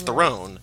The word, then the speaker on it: Theron: Theron like heroin.